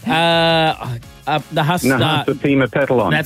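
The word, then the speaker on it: Nahasapeemapetilon: Was it A, Apu Nahasapimapetilian? – Yeah, close enough. – What was it? How do you say it? – Nahasapimapetilon.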